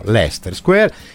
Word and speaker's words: Leicester Square: Andate a vedere un musical, se voi vi mettete in coda a Leicester Square, che si scrive Leicester Square, ma gli inglesi non si sa perché pronunciano Leicester Square, c'è una gabbiotta dove vende i biglietti della sera stessa a metà prezzo.